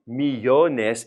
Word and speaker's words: millones: If it's three syllables, we stress the middle syllable. So now we don't need the accent. Millones. We're already stressing the middle.